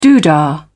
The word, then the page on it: doodah: British English